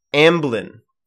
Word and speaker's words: Amblin: Did you say Albline? – Amblin. I believe is how it's pronounced. Amblin.